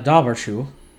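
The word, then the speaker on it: Dobhar-chú: In Wikipedia, the Irish pronounce this a dobbershoe.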